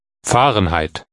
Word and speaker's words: Fahrenheit: Fahrenheit is pronounced Fahrenheit.